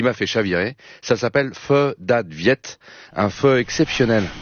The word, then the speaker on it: pho: Alors là, on est au nord du Vietnam et on va goûter un pho. C'est comme ça qu'on prononce pho ? – Pho. – Pho. – Pho. – Oui. En aucun cas, c'est faux. – Non. – C'est faux de dire faux.